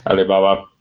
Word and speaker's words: Alibaba: What I would like to talk about, though, is China, and more specifically, Alibaba. Am I saying that right? – Alibaba.